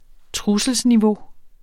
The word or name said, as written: trusselsniveau